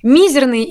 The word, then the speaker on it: мизерный: Мизерный или мизерный? – Ой, ну, по долгу своей службы с этим словом я сталкивался. И, конечно, не без э, удивления узнал, что нормой является вроде как мизерной.